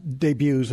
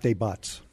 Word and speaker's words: debuts: And it's pronounced debuts and not debuts.